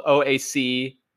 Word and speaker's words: Oaxaca: There's a place in Mexico called OAXACA, and it's pronounced Oaxaca. So it's like a W sound rather than O sound. It's like Oaxaca.